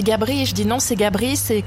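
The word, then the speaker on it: Gabris: Gabrice, hein, avec le S qui se prononce. – Les Français disent Gabri en général, ça doit vous énerver ou vous laisser couler?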